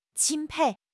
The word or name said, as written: 钦佩